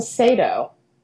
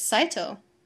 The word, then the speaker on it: Saito: I think everyone calls him Sato. – Oh, and it's Saito.